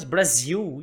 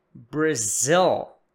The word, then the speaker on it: Brazil: Presta atenção na pronúncia de Brazil. Algumas pessoas dizem, nossa, mas tem que falar Brazil. Não, não preciso falar apenas. Não posso falar apenas Brasil e as pessoas vão entender. Algumas pessoas sim, outras talvez não vão entender.